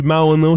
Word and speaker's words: Mauno: Et puis Mauno, c'est M-A-U-N-O. J'ai été chercher la prononciation parce que ça a l'air d'être mono. – Oui. – C'est Mauno. – Oh, d'accord. – Mauno.